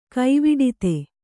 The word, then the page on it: ಗವುಡಿತಿ: gavuḍiti